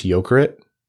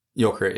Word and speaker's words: Jokerit: It's worth noting that I believe it's pronounced Jokerit. – Jokerit, yeah. – The Finnish team in the KHL, which is, the largely Russian professional hockey league, has withdrawn.